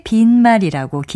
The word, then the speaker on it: 빈말: 형용사 비다에서 나온 것이기 때문에 빈말이라고 길게 발음하죠.